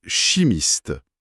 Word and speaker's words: chimiste: Question, vous êtes chimiste. Réponse, oui, je suis chimiste. Le che, là, comme dans chat ou chocolat. Chimiste.